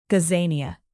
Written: gazania

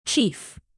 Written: chief

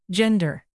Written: gender

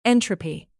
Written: entropy

